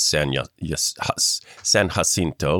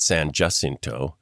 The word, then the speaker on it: San Jacinto: If you use this classic Spanish pronunciation, it's San Jacinto, like you said. Mm-hmm. But the locals there pronounce it San Jacinto.